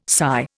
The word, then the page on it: SY: Indian